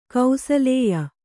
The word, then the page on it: ಕೌಸಲೇಯ: kausalēya